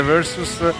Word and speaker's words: Versus: Non me ne voglia l'ascoltatore di prima che diceva che dice plus e non plus, parola anche inglese Versus, e quindi lo pronuncio come lo pronunciano i Pearl Jam stessi, anche se è una parola latina, anche questa che ha la provenienza è una parola latina, scherzando ovviamente.